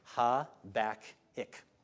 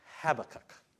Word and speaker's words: Habakkuk: But I'll acknowledge that I say Habakkuk. Habakkuk, okay? I think that's what most Americans say, okay? But I was listening this week to a British chap, you know, read this book, and he kept saying Habakkuk. And I thought, that sounds funny.